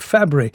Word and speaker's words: Fabares: Johnny Angel, Shelley Fabre. Now, interesting about the spelling of that name, it looks like Fabares, but no, they pronounce it Fabre.